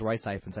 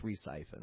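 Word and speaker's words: Ralph Fiennes: Actually, they say it in the trailer, and like, I've been saying it wrong all these years, because I thought it was Rice Siphons. I believe it's Re-Siphons.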